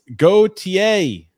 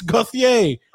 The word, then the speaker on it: Gauthier: It's Gauthier. Okay. That's how you pronounce it.